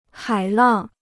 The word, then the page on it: 海浪: hǎi làng